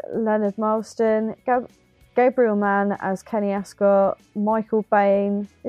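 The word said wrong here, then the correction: Biehn: Is that how you say his name? Is it Bain? – Bien, Michael Bien. – Oh, Bien.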